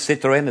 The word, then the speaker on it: Citroën: How's that, Yolande? Citroën? Is that okay? A little French pronunciation of Citroën.